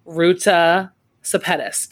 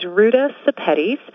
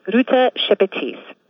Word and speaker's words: Ruta Sepetys: My name is Ruta Sepetys. And that's a Lithuanian name, and in Lithuanian, it would be pronounced Ruta Sepetys.